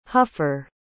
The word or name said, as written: huffer